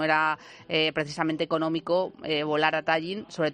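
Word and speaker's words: Tallinn: Tallin.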